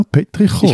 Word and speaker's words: Petrichor: Petrichor. – Wie heißt das? Das hast du jetzt aber sehr schweizerisch ausgesprochen. – Petrichor. Also, no, Petrichor.